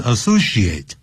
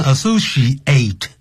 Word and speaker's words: associate: 好、啊、我呢当时面试你的时候来争什么这个职位呢 ?For the advertising associate, 也就是广告助理呢这个职位的时候 ,position, 这个 associate, 这个时候因为是形容词所以也可以当名词 associate, 不要念成 associate, 那就是动词了。